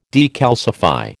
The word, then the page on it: decalcify: American English